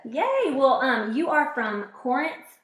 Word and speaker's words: Corinth: It's spelled C-O-R, but people from Corinth say Corinth, like Corinth. C-A-R.